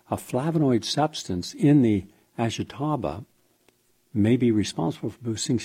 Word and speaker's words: Ashitaba: Many Japanese elders refer to the plant, and I'll spell it for you, Ashitaba, A-S-H-I-T-A-B-A, as the immortality plant, and believe it will keep you looking and feeling young.